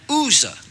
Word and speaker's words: Uzzah: And they carried the ark of God in a new cart out of the house of Abinadab and Uzzah.